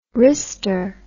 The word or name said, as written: wrister